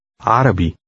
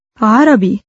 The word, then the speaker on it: عربي: عربي. عربي. – How do you say, excuse me, in Arabic? – عفواً. – Note that the beginning sound of the two words is the same. Say, Arabic. – عربي.